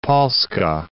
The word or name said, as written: Polska